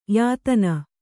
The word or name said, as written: ಯತ್ನ